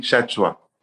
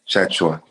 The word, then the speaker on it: Tchatchoua: So you pronounce it Chachua. It's Chachua. – From Cameroon because my parents are from Cameroon descent. So Chachua, T-C-H-A-T-C-H-O-U-A.